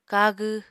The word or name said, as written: kagw